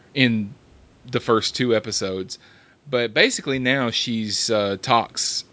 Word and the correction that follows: Voq: Am I saying that right? Tox, isn't that his name? – Vok. – I'm sorry. – V-O-Q. – V-O-Q, yeah.